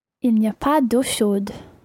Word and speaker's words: chaude: Eau is feminine, so chaude, you pronounce the D because it's got an E on the end.